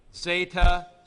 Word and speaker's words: zeta: Zeta is pronounced with a D, Z, zeta, z. Um, as a matter of fact, in Athens, at uh, uh, classical Greek times, they probably didn't pronounce it that way. They probably pronounced it just the opposite ZD, zd, like this.